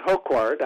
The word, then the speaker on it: Hocquard: So, her last name is spelled H-O-C-Q-U-A-R-D. So I said Hoquard. I hope that's correct. – You did good.